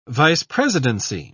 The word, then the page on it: vice-presidency: British English